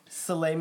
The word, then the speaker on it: Soleil: Soleil Moon Frye.